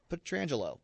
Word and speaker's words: Pietrangelo: I'm sure he's told him, hey, just a heads up, it's Pietrangelo. That's how you pronounce it.